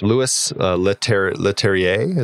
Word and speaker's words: Leterrier: And I mean, just kind of speaking to the way that these things unfold, like I think it was Louis Leterrier, is that how you say his name? – Yeah.